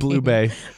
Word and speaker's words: Bublé: Bublé.